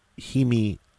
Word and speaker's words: hemiola: The placement of the linguistic accents in the original Ukrainian text of the chant also employ a device known as hemolia, or hemiola. I pronounce that correctly.